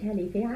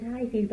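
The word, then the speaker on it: Hellifield: And I didn't know they called it Hellyfield. I called it Highfield, but it wasn't. It apparently it was Hellyfield.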